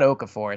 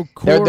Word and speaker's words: Okorafor: So now they have, uh, and I'm gonna mispronounce his name, but oh, oh it's not Okafor. It's not like Jaleel Okafor, but um, o- Okorafor, their, right tackle.